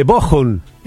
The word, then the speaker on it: Bochum: De vuelta Raúl desde Bochum. Y me dice, bien pronunciado, con CH, entre dos vocales se pronuncia como J.